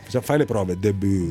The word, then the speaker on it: De Boer: Allora, ci sentono anche dalle Fiandre e ci correggono facendo i complimenti, ma non si pronuncia De Boer, ma De Boer. – De Boer.